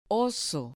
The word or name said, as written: oso